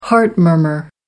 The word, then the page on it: heart murmur: American English